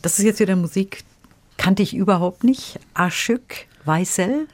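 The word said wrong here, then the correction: Aşık Veysel: ist das richtig ausgesprochen? Aschek Weissel. – Mhm. Aschek Weisel. – Mhm. Weisel. Ja.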